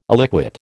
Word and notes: illiquid: American English

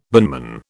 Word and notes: binman: British English

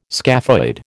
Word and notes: scaphoid: American English

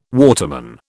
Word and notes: waterman: British English

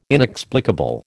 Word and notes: inexplicable: American English

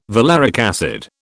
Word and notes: valeric acid: British English

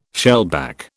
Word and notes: shellback: British English

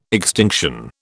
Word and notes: extinction: British English

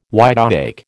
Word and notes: wide awake: American English

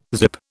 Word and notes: zip: British English

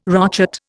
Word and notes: rochet: Indian

